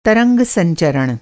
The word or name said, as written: संचरण